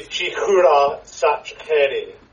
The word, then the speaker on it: Chikhura Sachkhere: it should be Chikhura Sachkhere. – Okay, I wasn't rolling my K's or R's enough, clearly.